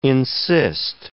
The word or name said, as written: encyst